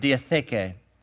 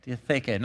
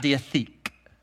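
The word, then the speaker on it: diatheke (διαθήκη): And uh, in Greek, you pronounce this word diatheke. Diatheke, not diatheik, Diatheke.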